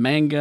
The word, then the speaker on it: manga: It's manga. – Manga? – I've been saying manja. – Manja? That's something different. – What the fuck is manja? – That's coming to Nevada soon. – No, that's just weed. – It's ganja. – Ganja. – Yeah, well, what the fuck is manja then? Why am I saying it right? – You're saying it wrong.